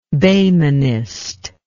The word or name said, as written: behmenist